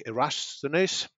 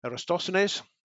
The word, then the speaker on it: Eratosthenes: And one ancient Greek, Eratosthenes, I can't pronounce his name properly, I'm sorry, Eratosthenes, maybe that's better,